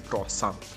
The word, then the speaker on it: croissant: Ya sabes, en México le decimos cuernito, pero yo esforzándome así para asegurarme que lo pronuncio bien, croissant.